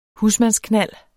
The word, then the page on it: husmandsknald: [ ˈhusmansˌknalˀ ]